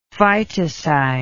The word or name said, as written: phytocide